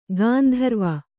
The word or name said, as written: ಗಾಂಧರ್ವ